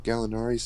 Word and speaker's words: Gallinari: Danilo Gallinari, is that how you say his name? – Yeah. – Danilo Gallinari signs 3-year deal with the Hawks.